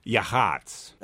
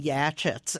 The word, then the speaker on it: Yachats: Yahats. Y-A-C-H-A-T-S. It rhymes with hots. – Okay, so that's why I've been going around saying Yachits, and nobody knows what I'm talking about.